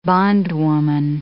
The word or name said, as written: bondwoman